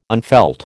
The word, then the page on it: unfelt: American English